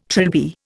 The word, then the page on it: trilby: Indian